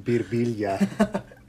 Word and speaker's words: Birbiglia: It's Italian, and it's pronounced Birbiglia.